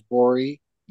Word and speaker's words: Gborie: The dreaded silent G at the beginning, that's really something, because no one's ever going to guess that, right? That there's the G there when you say my name's Gborie.